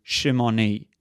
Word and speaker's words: Shimon: And Simoni is exactly the same as the Hebrew Shimoni. The Hebrew word Shimoni means Simeonite. Simeon in Hebrew is properly pronounced Shimon.